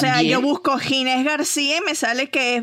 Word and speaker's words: Ginés: Ginés, ¿cómo se escribe? ¿Con J? – Ginés. – Con G. – G-I-N-E-S García.